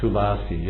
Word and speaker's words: Tulasi: Yes, Tulsi is not a long A. Tulasi, yeah.